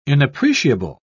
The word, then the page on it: inappreciable: British English